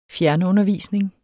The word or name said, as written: fjernundervisning